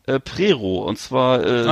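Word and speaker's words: Přerov: Regenbogen Camping Prero, geschrieben äh, für Westdeutsche Prerov mit OW am Ende wird aber O ausgesprochen, so wie die ganzen, Orte.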